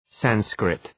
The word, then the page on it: Sanskrit: ‘sænskrıt